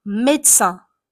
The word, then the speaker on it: médecin: Le premier, c'est les médecins, médecins, médecins. On écrit médecin, mais on prononce médecin.